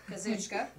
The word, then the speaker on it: Kazuchika: Kazuchika.